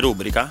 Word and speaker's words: rubrica: Cioè, ma è stato già detto che Martin fino a 2 anni fa pensava che rubrica si pronunciasse rubrica? – Sì.